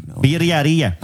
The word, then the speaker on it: birria: How do you say that, BJ? – Say birria. – Berriaria. – Stop playing. Say it again. – Berriaria. – Oh, no. – Birriaria. – All right, much better.